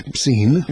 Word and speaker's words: ibsin: Ibsin ma tibdiex blie, sewa li għax it-tieni sillaba in ibsin hija twila, allura tqassar l-ewwel sillaba.